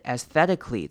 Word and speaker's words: aesthetically: 接下来下一个字 aesthetically 这个字比较难念一点。首先开头的 A-E 呢，我们会念成 a。念成 a 就是短母音 e 的声音，不用管前面的 a。